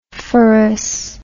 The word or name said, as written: phorous